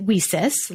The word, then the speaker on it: WiCyS: We often go by our acronym, WICYS, and we pronounce it WESIS, like we sis, we sisters, because that's exactly what we are.